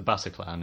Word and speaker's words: Bataclan: Yeah, so the concert venue was the Bataclan, is that how you pronounce it? – Yeah.